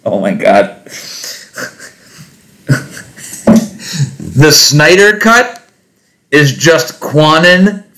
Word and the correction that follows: QAnon: for nerds. – QAnon. It's just QAnon for nerds.